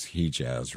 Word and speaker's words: Hejaz: I did go back and make sure I could pronounce Hejaz right, and that's how you say it. So it is H-E-J-A-Z.